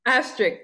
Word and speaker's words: asterisk: I put a little extract next to it, a little S, how do you say that word? – Asterisk. – Asterisk, yes. I almost want to say extract, like vanilla extract.